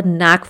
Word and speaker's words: knack: The fourth one is to have a knack for something. When you have a knack for something, knack means K-N-A-C-K. Of course, the K is silent here.